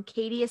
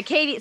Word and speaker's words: Caitie: Katie is spelled C-A-I-T-I-E. And then Fredrickson is the name Fred, the name Rick, and the word son. So anyway, but the Katie, so Fredrickson is very standard, but Katie has two I's.